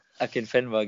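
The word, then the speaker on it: Akinfenwa: Äh, Akin Akinfewa, ich weiß ah, nicht. Adebayo Akin Fenwa, genau, genau.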